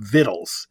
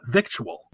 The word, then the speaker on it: victual: And I used to watch a show many years ago, the Beverly Hillbillies, and Granny would always have vittles. It has like a Southern intonation to it. And that's the correct pronunciation. The C-T that came in much later, and it's still pronounced vittle, not victual.